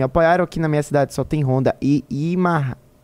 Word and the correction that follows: Yamaha: Imarra. Como é que fala? – Não sei. – Yamaha. Yamaha. Alguma recomendação?